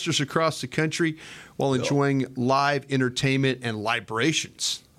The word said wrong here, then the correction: libations: Did I say that right? – Libations.